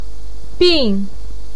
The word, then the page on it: bin4: pinyin